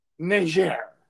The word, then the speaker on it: Niger: Niger. Yes. – So the guy like on the left rhymes with Karine Jean-Pierre. Just think of it that way.